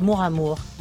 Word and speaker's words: mora mora: Ça se prononce « mora mour », comme l'amour en fait.